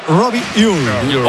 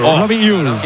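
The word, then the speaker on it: Robbie Ure: A ver, el club pasó incluso un audio, lo ha pasado. Y en concreto sería Robi Uy. Robi Uy.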